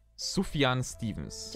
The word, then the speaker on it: Sufjan Stevens: Er heißt Sufjan Stevens. – Der heißt so, ja. – Studentenfunk, dein Podcast im Netz. – Sufjan Stevens immer wieder sehr, sehr schön.